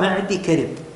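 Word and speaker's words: معديكرب: أي خاطئة، هو نطقها معدي كرب بإسكان الياء اللي مثل هذه، مثل قالي قلا، بعضهم ينطقها قالي يقلا، لا هي قالي قلا.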